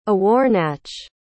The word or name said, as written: awarnach